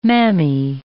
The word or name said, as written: mammy